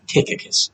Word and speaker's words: Tychicus: Tychicus.